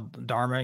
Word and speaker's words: dharma: And I can think you call it your dharma. Is that how you pronounce that? – Yeah, dharma. Yeah.